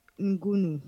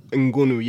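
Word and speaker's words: Ngounoue: Ngunu, yeah, I think that is how you say it. – Ngunu, I don't think, maybe you don't pronounce the N from my, because it's just from Cameroon, then it would sound more like French exactly.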